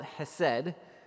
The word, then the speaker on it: hesed: This word is called hesed, H-E-S-E-D, in English. Can you guys pronounce that with me, hesed? All right. Some of you need a little more, like, Hebrew in your life.